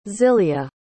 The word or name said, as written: zilya